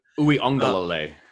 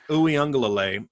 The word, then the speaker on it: Uiagalelei: because he'll do to you what DJ Uiagalelei, right? Is that, am I finally saying it right? – Uiagalelei. – Uiagalelei. – There you go.